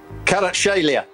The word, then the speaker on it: Kvaratskhelia: Kvaratskhelia.